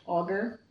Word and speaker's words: augur: She corrects your pronunciation of that word. Is properly pronounced augur.